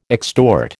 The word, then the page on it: extort: American English